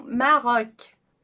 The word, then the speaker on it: Maroc: Mas é importante você saber, então, que a gente vai pronunciar Maroc. Maroc. Tá? Você observa ali que só tem uma consoante, que é o C, né? E a gente vai pronunciar o O com som de O, Maroc, Marrocos, ok?